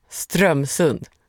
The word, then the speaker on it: Strömsund: Strömsund uttalas med betoning på första stavelsen. Strömsund. – Strömsund.